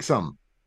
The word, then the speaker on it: Sumn: This is Shake Some. I'm going to say, assume that the N is silent. It's Shake, S-U-M-N.